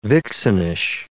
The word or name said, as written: vixenish